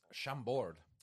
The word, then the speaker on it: Chambord: It's Chambord.